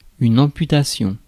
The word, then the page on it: amputation: /ɑ̃.py.ta.sjɔ̃/; France